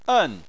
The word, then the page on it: en: 4